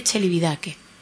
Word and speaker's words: Celibidache: Se escribe Celibidache. Tal y como lo digo, así con B. Y se dice Celibidache.